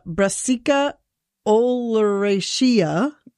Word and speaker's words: Brassica oleracea: So this one is interesting, and I don't know how to say the name of this plant, but I will give it a try. Right. Depending on how it's grown, the Brassica oleracea nailed it.